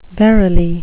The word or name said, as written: verily